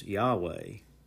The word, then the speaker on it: Yahweh: But it's written through consonants that sound like YHWH. And when you put some vowels with that, what we've come up with to pronounce is Yahweh.